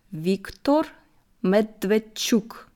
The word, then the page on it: Viktor Medwedtschuk: slow